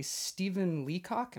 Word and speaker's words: Stephen Leacock: Corked was one of the best books of 2009 by Quill and Quill Magazine, and it was also nominated for a Stephen Leacock, am I saying that right? – Yeah.